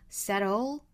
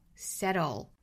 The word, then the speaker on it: settle: 那美式发法会把它发成 flap t，那会发成一个 settle settle，后面 the answer becomes clear，这个 answer 美式发法是 answer answer，那 the 和后面的 answer 在连读同样是要加耶。